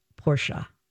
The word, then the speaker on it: Porsche: And it is pronounced Porsche.